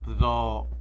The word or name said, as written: bdo